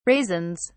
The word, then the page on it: raisins: British English